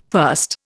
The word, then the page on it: first: Indian